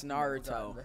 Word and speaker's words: Naruto: That and a couple other like animes like I really want to watch Naruto. – It's Naruto. It's Naruto. Please pronounce Naruto. – It correctly.